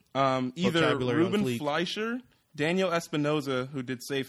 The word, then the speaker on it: Fleischer: Fleischer? – Ruben Fleischer.